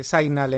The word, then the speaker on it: Signal: Esto se llama Signal. ¿Cómo se dice esto? ¿Esto es como el dentífrico o cómo es esto? – Sí, Signal en inglés, imagino que se pronuncia, eso es.